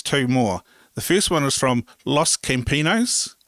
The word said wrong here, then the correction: Campesinos: I don't speak any type of Spanish, so I hope I pronounce that correctly. – I think it's Campesinos.